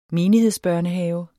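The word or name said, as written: menighedsbørnehave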